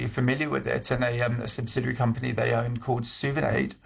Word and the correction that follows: Souvenaid: How do you spell it? – So it's Souvenaid. So Suvenaid.